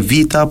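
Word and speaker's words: Βήτα: Η προφορά για το όνομα του γράμματος τούτου άλλαξε μέσα στους αιώνες, επί έμπου ΜΠΕΤΑ, σε ΠΕΤΑ, σε ΠΙΤΑ, σε ΒΙΤΑ και τέλος σε ΒΙΤΑ που έχουμε σήμερα.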